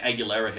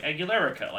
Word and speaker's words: Aguilera: But when it came out, I was super young when Christina Aguilera hit. Did not know how to pronounce her last name until someone, I actually like heard someone say it. I was like, Aguilerica. Like, I didn't know.